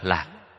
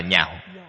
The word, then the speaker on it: nhạo: Câu sau cùng, bác ngôn tận ái lạc, cái chữ này đọc chạy âm, không đọc là lạc, đọc là nhạo.